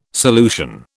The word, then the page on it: solution: British English